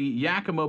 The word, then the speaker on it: Yakima: Anyway, I don't know how to say the name of this town in Washington. Yakima? Is it actually Yakima? – Yakima. – Oh, okay, cool. – I think I'm almost positive it's Yakima.